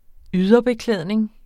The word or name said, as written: yderbeklædning